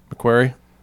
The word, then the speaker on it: McQuarrie: Because it's McQuarrie. – Oh, Ralph McQuarrie. – McQuarrie.